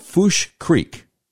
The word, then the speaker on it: Fouche: Unfortunately, that meant that I was on a small bridge over something called Fouche Creek. That's how you say it, F-O-U-C-H-E. I guess it's French.